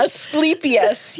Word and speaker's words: Asclepius: Asclepius yes.